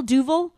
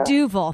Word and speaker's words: Duvel: I'm still drinking Duvel? – Well, Duvel, yeah. – Duvel, thank you. I'll pronounce it properly, but I'll continue to drink it.